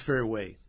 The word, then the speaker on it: Fairway: I'm saying that wrong, aren't I? – It's Fairway, like golf.